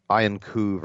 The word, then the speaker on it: Iancu: It's, it's actually, I believe pronounced, Iancu versus Brunetti.